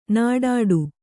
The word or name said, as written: ನಿಟ್ಟಡಿ